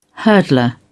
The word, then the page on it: hurdler: British English